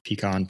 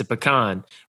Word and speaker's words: pecan: Did I say it correctly? – No, you did not. – Wrong. – See, there's an argument there. – I'll tell you an abridged version of what BJ Barham once said. He goes, look, I've looked up the dictionary pronunciation. He goes, I get it. It's a pecan tree. It's a pecan. But the moment you take and put it in there and the sugar hits the pecan, it becomes pecan pie.